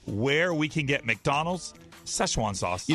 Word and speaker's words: Szechuan: I guess this is pronounced Szechuan sauce. – It's Szechuan. Been gone. – Szechuan sauce. – Yeah, Szechuan. – Szechuan.